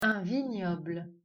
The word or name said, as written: Vignoble